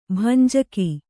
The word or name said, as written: ಭಂಜಕ